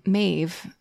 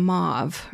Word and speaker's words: Maeve: She talks about how people can't pronounce the name Maeve. She gets a lot of Mauve or Maeve.